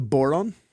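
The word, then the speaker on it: bodhrán: Well, I brought the Irish drum, which is called the boron. Conveniently rhymes with moron. – Oh, but it's spelled B-O-D-H-R-A-N. – Because I call it called the Bodran.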